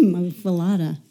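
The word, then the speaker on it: muffuletta: Barbara introduced me to a muffaletta or muffalata. How do you say muffalata? We say muffalata.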